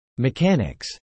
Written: mechanics

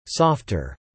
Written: softer

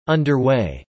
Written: underway

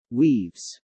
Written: weaves

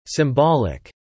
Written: symbolic

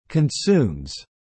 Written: consumes

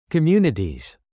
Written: communities

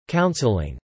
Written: counseling

